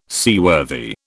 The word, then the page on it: seaworthy: British English